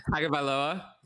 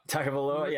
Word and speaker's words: Tagovailoa: Tua, I'm not going to go over his last name because I'm not good at pronouncing it. – Tagovailoa. – Tagovailoa, yeah. Tagovailoa.